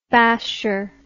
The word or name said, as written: basher